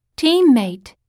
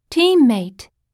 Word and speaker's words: Teammate: Teammate. Teammate. – この単語も最初の team の方を強く発音するよ。そして ea は e と伸ばして発音しよう。